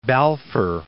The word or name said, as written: Balfour